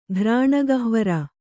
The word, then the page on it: ಘ್ರಾಣಗಹ್ವರ: Kannada